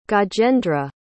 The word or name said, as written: gajendra